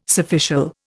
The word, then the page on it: surficial: Indian